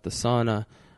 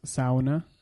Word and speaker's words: sauna: What book were we discussing at the sauna, where like the like boy geniuses and boy wonders? – It's actually pronounced, sorry, it's pronounced sauna.